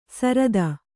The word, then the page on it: ಸರದ: sarada